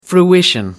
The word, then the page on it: fruition: American English